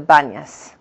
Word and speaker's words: Banias: but in Hebrew today we call it the Banias, which is really from the Arabic pronunciation of Panias.